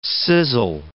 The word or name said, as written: sizzle